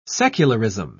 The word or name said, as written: secularism